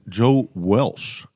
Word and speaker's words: Walsh: I say Leadon, and then Joe Walsh. – I'm like, who's Joe Welsh? I said, this is the part I don't know. Joe Welsh, brand new. – Well, that's how you pronounce it.